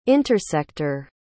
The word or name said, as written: intersector